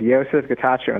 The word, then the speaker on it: Yosef Getachew: Yes, my name is Yosef Getachew, and thanks for having me, Tom.